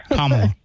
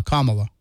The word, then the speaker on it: Kamala: Because I know a lot of people are afraid of Kamala. I can't ever say her name right either. – Kamala. Think about like a comma, you know, like when you're writing a sentence, a comma and then la, Kamala.